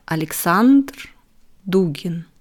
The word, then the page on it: Alexander Dugin: slow